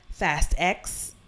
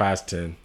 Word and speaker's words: Fast X: Also, you have the Fast X. Fast 10. I don't know how you say it. – Fast 10. – Oh, I said Fast X. Fast 10.